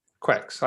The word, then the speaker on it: Quaich: Let's go for the Centenary Quex, I think it's pronounced. – Centenary Quex. – It's spelled Q-U-A-I-C-H. – Q-U-I. – Quex. – Quex. I think that's it. – Sounds like Scottish for quiche. Q-A-I-C-H, or like bad weather in the Hebrides.